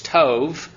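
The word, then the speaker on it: tov (טוב): T-O-B, the bait in Hebrew lacks the dogesh, so it's pronounced like a V rather than a B. It's tov.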